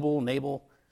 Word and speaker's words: Nabal: And there's argument even over how you pronounce Nabal's name. Nabal. We're going with Nabal today.